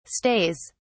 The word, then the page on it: stays: British English